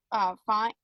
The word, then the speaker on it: Fiennes: It stars Joseph Fiennes? Is that how you say it? – That's right. Yeah, that's right.